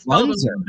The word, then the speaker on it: Lanser: Back on PT Pintcast, Bailey Lanser. Am I saying that right? – Uh, it's it's actually Lunzer, mm. but it's Lunzer.